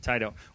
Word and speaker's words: Taito: Taito. – Warner Brothers is talking to Taito to get the rights to make a film out of the video game Space Invaders.